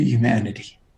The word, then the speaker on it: humanity: Before I die, I want to see the world move more closely to humanity. Humanity. I have to pronounce the H. I'm from Brooklyn. We don't normally do that.